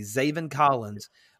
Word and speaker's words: Zaven Collins: Zaven Collins. I think I pronounced that name correctly. Zaven Collins. – That's correct.